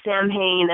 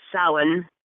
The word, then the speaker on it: Samhain: Some people pronounce it Samhain as it is spelled, but it is actually pronounced Samhain. And because it's a Gaelic word.